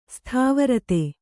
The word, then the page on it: ಸ್ಥಾವರತೆ: Kannada